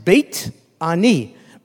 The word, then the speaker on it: Beit Ani: It's in Hebrew, it's pronounced Beit Ani. Beit means house, Ani, of the poor.